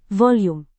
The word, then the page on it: volume: Indonesian